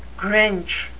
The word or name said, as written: grinch